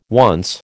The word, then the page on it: once: American English